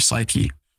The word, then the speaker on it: psyche: And then lastly, the last S, it's a P, but you know, the P is silent, touches your psyche, your thoughts, the story that you tell yourself about yourself and about the world.